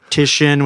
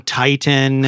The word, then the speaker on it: Titian: So Titian, which is a Renaissance artist, I was calling Titan.